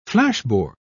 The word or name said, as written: flashboard